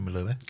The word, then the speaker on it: Melua: Is it Katie Melua or Katie Melua? – Melua.